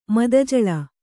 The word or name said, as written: ಮದಜಲ